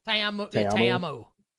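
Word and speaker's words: Ta'amu: Is it actually like Te'amu? Is that how I pronounce it? I think it's like Te'amu. Te'amu.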